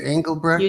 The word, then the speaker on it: Engelbrecht: Is it Engelbrecht? – You did.